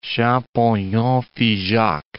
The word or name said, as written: Champollion-Figeac